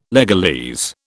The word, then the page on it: legalese: British English